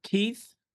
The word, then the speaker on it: Keith: Keith, K-E-I-T-H, Mark, M-A-R-K, and I'm the founder and president of Hunter Nation, H-U-N-T-E-R, capital N-A-T-I-O-N.